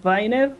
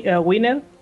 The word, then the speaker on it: Wiener: Sí, en efecto, Norbert Wiener o Wiener, depende de dónde decidas pronunciarlo, ¿no? – Exacto.